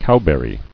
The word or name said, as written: Cowberries